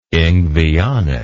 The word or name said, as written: Ingveonic